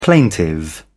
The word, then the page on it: plaintive: British English